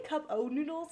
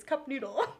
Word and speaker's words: Cup Noodle: I always say cup O noodles. And I know there's no O there. Like, you're saying it correctly. It's cup noodle.